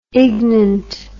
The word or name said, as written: ignant